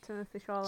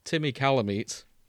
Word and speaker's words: Timothée Chalamet: Sorry, I vowed to pronounce his name wrong forever. Timmy Calamite is probably going to be in there. Quite popular. I've gained more respect from now I've seen Dune, but I'm still going to pronounce it like that, because that's how it looks as if it should be said.